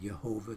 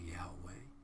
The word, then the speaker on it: Yahweh: Some pronounce it Jehovah, it's more accurately Yahweh.